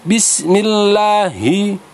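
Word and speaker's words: Bismillahi (بِسْمِ اللهِ): billahi Padahal yang benar adalah Bismillahi. Kalau bilai itu menjadi ya sukun didahului fathah bilai.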